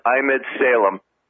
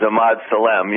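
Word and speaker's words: Emad Salem: If you Google his name, it's E-M-A-D and then S-A-L-E-M, pronounced Ahmed Salem. I used to think it was Ahmad Salem, you know, when I was on with you in 05, but now he told me how to pronounce his name correctly.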